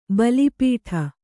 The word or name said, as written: ಭಾಲಪಟ್ಟ